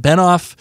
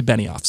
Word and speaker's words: Benioff: for correcting me on my pronunciation of David Benioff. I was saying it as though it was David Benioff. And you, as you know, I am a stickler for pronunciation and making sure getting names are right. – You are. – I've heard both pronunciations, so I was like, you know, I'm just going to pick one. So I went with Benioff, but it's actually Benioff.